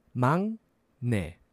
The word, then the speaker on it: 막내: So that's 막내. Now it's spelled like 막 with the G sound, but it's 막내.